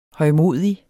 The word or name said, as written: højmodig